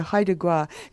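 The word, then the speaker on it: Haida Gwaii: I was quite interested in some of the things people were saying about Haida Gwa. Is that how you say it? – That's exactly right, yes. – Yeah, Haida Gwa.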